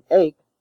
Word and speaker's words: ache: The CH in ache stands for the K sound. R, A-R-E, rhymes with car. Have, H-A-V-E, although it is in the cave-save spelling family, is pronounced have.